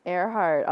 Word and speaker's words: Earhart: Earhart, although it is spelled E-A-R-H-A-R-T, Earhart.